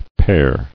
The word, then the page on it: Pere: père